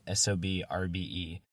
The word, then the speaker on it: SOB x RBE: Which brings us to Gangin, from Vallejo, California quartet SOB RBE. Yes, the X between the two halves of their name is silent.